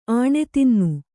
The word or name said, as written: ಆಣೆತಿನ್ನು